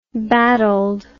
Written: battled

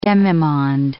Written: demimonde

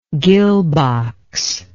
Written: gill box

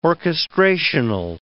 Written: orchestrational

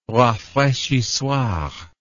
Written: rafraichissoir

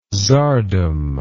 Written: tzardom

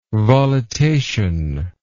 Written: volitation

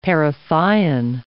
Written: parathion

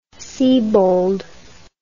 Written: siebold

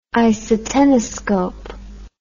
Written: isoteniscope

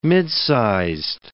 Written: midsized

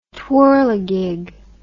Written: twirligig

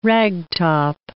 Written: ragtop

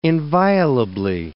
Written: inviolably

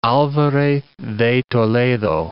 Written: alvarez de Toledo

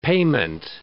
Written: payment

